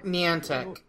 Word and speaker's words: Niantic: With the Pokemon Company and Niantic. Is that how it's pronounced? – Niantic.